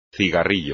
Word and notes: cigarrillo: espagnol

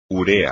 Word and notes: urea: espagnol

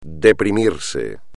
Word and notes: deprimirse: espagnol

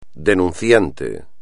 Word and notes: denunciante: espagnol

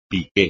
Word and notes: piqué: espagnol

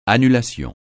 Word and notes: annulation: francais

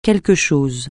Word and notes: quelque chose: francais

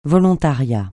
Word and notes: volontariat: francais

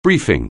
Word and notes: briefing: anglais

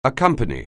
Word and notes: accompany: anglais

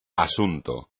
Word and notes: asunto: espagnol

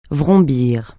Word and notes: vrombir: francais

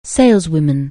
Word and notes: saleswoman: anglais

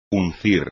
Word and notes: uncir: espagnol

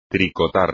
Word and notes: tricotar: espagnol

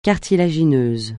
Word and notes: cartilagineux: francais

